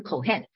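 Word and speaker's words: Kohen (כהן): 祭司这个词儿哈， Strong 的这个希伯来原文的字典的号是 H3548，它的发音就是 Kohen。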